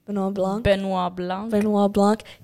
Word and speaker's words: Benoît Blanc: But how do you say Benoît? – Benoît Blanc.